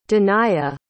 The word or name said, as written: danaiya